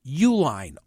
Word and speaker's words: Uihlein: Nearly a third of Mastriano's fundraising, nearly a third, came from one wealthy company, Richard and Elizabeth Uihlein, although it's spelled Uihlein, billionaire conservative donors who live in suburban Chicago.